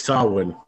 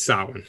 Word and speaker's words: Samhain: S-A-W-I-N? – No. It's spelled S-A-M-H-A-I-N. So you'll hear a lot of people try and say Samhain. – But it's Samhain. – It's Samhain. Yeah.